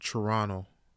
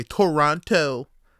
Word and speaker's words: Toronto: You don't say Toronto, Toronto. That's how you say it. And low key, that's how that's how I know when people ain't from here. When they pronouncing the T's way too heavy.